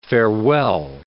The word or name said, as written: farewell